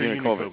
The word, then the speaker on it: Yanukovych: Viktor Yanukovych, yeah.